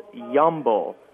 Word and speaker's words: Yambo: I don't know how it's spelled, but the way they say it is Yambo. Kind of like Y-A-M-B-O.